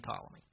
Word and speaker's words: Ptolemy: The Ptolemies, all of them are named Ptolemy, every one of them. And that's spelled with a P. The P is silent.